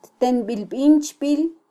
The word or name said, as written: ttenbilbinchbil